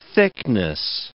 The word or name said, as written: thickness